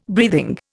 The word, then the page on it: breathing: Indian